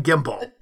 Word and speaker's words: gimble: Pronounced slithy as if the two words slithy make the G hard in gyre and gimble.